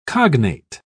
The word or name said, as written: cognate